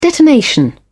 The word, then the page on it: detonation: British English